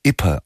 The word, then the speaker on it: Ypres: Die Tindersticks mit einem vergleichsweise stillen, einem Instrumentalalbum mit dem Titel, ich hatte es gesagt, Ypres. Für mich schwierig auszusprechen, denn Ypres eigentlich geschrieben, Ypres ausgesprochen mit einem R, das so unmerklich hinten dran gehängt wird.